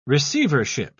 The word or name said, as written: receivership